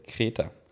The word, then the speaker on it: Greta: In German, we say Greta. Greta.